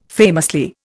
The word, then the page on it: famously: Indian